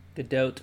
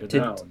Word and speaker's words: Gadot: Which I guess is pronounced Gadot. – Gadot. – Oh, no. – Like Gadot. To dote on someone.